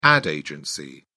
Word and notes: ad agency: British English

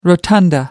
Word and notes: rotunda: American English

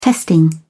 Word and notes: testing: British English

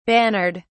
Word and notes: bannered: British English

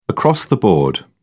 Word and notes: across-the-board: British English

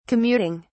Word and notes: commuting: British English

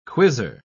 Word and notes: quizzer: British English

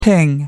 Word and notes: ping: American English

